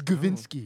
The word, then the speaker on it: Gavinski: Cam Kavinsky. Did I say it right? Kavinsky. – No, it's Gavinsky. No.